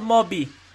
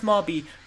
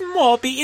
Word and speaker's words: mauby: You read the menu and find a word spelled M-A-U-B-Y. You know that there is a drink pronounced Marby because you heard me say Marby. You heard this. So you see this word. There is no other word that starts with M on the menu. So tell me this here. You knew that that was the drink that I was talking about, but you choose to say it wrong. It is Marby.